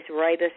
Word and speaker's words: rooibos: Our other drink of choice at my home is rooibos red tea, and it sounds like the man's name Roy and the school bus, Roy Bus. But it's from South Africa. It's spelled R-O-O-I-B-O-S, not flavored, just the rooibos.